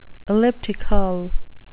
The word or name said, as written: elliptical